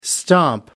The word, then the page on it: stomp: American English